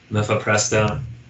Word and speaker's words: mifepristone: So this isn't going to be a Brooklyn Byte about mifepristone. – My favorite medicine that's being sold? Yes. – I have no idea if I'm pronouncing that. – No, I think you hit it right.